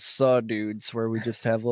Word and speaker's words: Suh: Cause it's Sue, baby. That's how you say it.